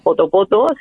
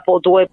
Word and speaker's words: Poto Poto: No se pronuncia Poto Poto, se pronuncia potue, potue o algo así, me comentaron,